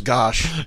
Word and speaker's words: Gosch: I know it looks like umlautski, but it's pronounced gosh.